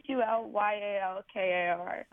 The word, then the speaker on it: Hulyalkar: Her last name is spelled Hulyaker, and Viveka is with a K. – It's actually Hulyalkar.